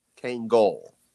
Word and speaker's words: Kangol: I was going to ask, no kangaroos, but a lot of leftover Kango hats. Kango? How do you say it? – I think it's got the L at the end for LL Cool J. – Kango.